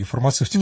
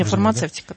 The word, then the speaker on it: фармацевтику: Но параллельно все-таки надо развивать фармацевтику. Я правильно говорю, вечно путаюсь в фармакологии. Фармацевтику. – Не, фармацевтика, да.